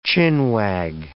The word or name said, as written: chin-wag